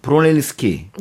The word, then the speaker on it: 브루넬리스키: 뭐냐면 여기에 동상에 있는 브루넬리스키 네.